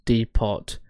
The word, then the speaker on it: depot: Jamie drove up to the local bus depot. Is that how you say it? – Depot. Silent T.